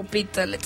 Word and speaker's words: Hospitalet: en su peluquería del centro de Les Hospitalet sin pagar antes. ¿Lo pronuncié bien? No, ¿verdad? – Hospitalet, sí.